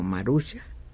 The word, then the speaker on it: Maruxa: Esa es una pequeñísima, pequeñísima muestra de la obra de que me dice Oscar que lo correcto es pronunciar su nombre como Maruxa.